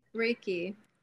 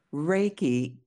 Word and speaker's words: Reiki: Reiki? – Reiki. – Reiki.